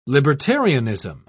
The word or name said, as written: libertarianism